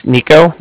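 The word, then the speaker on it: Niko: It's Nico, that's N-I-K-O,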